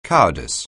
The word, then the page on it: cowardice: anglais